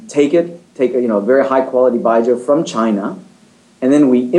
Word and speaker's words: Byejoe: We called it Baijiu, B-Y-E-J-O-E, so that any Joe in America could pronounce it.